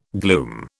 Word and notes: glume: British English

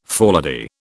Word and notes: forelady: British English

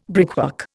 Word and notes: brickwork: Indian

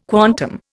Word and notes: quantum: Indian

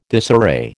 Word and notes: disarray: American English

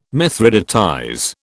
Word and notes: mithridatize: British English

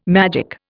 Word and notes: magic: Indian

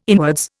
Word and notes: inwards: Indian

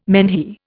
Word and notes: menhir: Indian

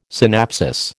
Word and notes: synapsis: American English

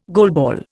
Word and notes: goalball: Indian

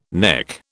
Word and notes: nek: British English

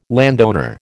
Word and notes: landowner: American English

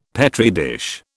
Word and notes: Petri dish: British English

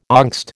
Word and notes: angst: American English

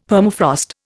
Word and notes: Permafrost: Indian

